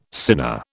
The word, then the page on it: sinner: British English